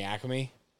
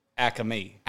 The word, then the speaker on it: Acme: So that's interesting you said it like that. Guy like you? Acme. – Acme. – Three syllables.